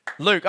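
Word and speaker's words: Luc: Luke.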